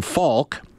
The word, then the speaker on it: Falk: and that's the Falk that's spelled F-A-L-K, not the Falk that is spelled F-A-U-L-K of the Carolina Hurricanes,